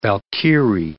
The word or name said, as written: Valkyrie